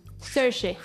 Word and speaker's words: Saoirse: ¿Cómo se pronuncia? pronuncias a Orsi Ronan? – Saoirse.